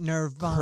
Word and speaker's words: Nirvana: That's how you say it. Nirvana.